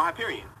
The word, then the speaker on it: Hyperion: Oh, Hyperion. – Yeah. – Yeah, I wore those into the ground, and I'm thinking of getting another pair of those. – Oh, they're coming out with a new Hyperion line.